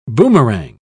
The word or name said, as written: boomerang